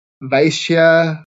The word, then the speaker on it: Vaishya: Vaishya are sort of tradesmen and farmers.